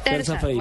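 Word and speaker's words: terça-feira: Tercera feira. – Terza. Terza. – Terza, feira. Bueno, terza feira, sí.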